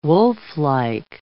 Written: wolflike

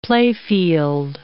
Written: playfield

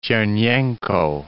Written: Chernenko

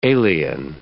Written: alien

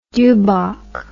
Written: dubok

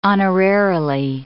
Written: honorarily

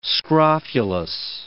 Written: scrofulous

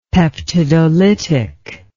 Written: peptidolytic

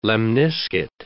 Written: lemniscate

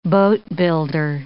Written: boatbuilder

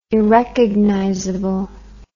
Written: irrecognizable

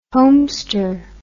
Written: homester

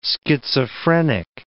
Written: schizophrenic

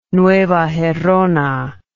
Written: Nueva Gerona

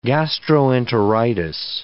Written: gastroenteritis

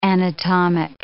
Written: anatomic

